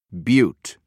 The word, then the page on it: butte: British English